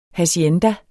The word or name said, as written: HACIENDAS